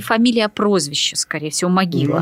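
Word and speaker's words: Movilă: Его отец Симеон Могила или Мовиле по румыно-молдавскому произношению, а это более правильное, а Могила – это его стали уже называть так на Украине.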